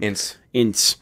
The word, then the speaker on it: Ince: Ince.